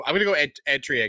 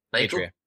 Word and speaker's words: Eritrea: I'm going to go, because I think it's to do with the Red Sea, so I'm going to go Eritrea again. I'm saying it wrong now. No, I hate it. – Eritrea.